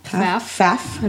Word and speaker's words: Pfaff: I think her last name's Paff. – Faff. I think the P is silent. – Yeah. – P-F-A-F-F.